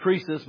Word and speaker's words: Croesus: Now, it's pronounced Croesus, but it's C-R-O-E-S-U-S.